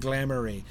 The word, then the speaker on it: glamoury: Then coming home with honeycomb and money none to memory, his message came and errand too, in daring do and glamoury, to rhyme with memory.